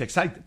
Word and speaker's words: Exide: Se escribe Exide, se pronuncia Exide, dentro decía el comercial de las baterías.